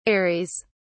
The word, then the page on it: ares: British English